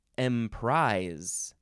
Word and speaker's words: emprise: Next is emprise. Emprise. It is spelled with an S, not a Z, even though it sounds like it's a Z. Emprise.